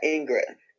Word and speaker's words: Ingrid: Yeah, Ingrid.